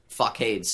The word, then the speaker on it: facade: Facade. Is how that word is pronounced. – Oh, fuck. What did I... – Facade. That's the second time you've done that on the podcast.